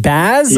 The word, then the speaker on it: Baz: Your name is spelled B-A-Z, so is it Baz or Boz? – Yeah, definitely Baz, not Boz. – Okay.